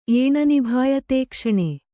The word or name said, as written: ಏಣನಿಭಾಯತೇಕ್ಷಣೆ